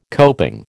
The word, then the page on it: coping: American English